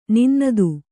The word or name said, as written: ನಿನ್ನದು